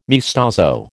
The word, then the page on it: maestoso: American English